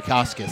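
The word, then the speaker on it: Bukauskas: I think I said that right. Bukakis. – Bukakis. – Yep.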